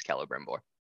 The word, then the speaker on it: Celebrimbor: I say Celebrimbor. The game Shadow of Mordor, which isn't technically canon but follows Celebrimbor, says Celebrimbor, but also the official like pronunciation is Celebrimbor.